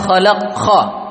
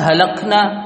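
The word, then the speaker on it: khalaqna (خَلَقْنَا): Imamnya membaca surat At-Tin ini, surah At-Tin, dia membacanya yang seharusnya dibaca laqad khalaqna. Dia baca laqad halaqna. Tak bisa, tak bisa kha dia.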